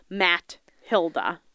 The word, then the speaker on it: Mathilda: And it's spelled like M-A-T-H-I-L-D-A. Ew. Like Matt Hilda.